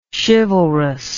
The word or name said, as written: chivalrousness